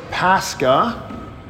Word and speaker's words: Pascha: We pronounce this Pascha, and that's the word for Passover.